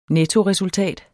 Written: nettoresultat

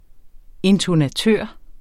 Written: intonatør

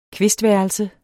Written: kvistværelse

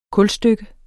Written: kulstykke

